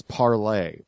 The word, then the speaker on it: Parler: Now, the interesting thing about this is that the name of the site is Parler, pronounced like the French verb to speak. But people didn't like that shit, so they just started calling it Parler. So the official pronunciation of the name has now been changed to Parler. – But it's spelled Parler? – Well, that's how P-A-R-L-E-R is how you spell the French verb, Parler.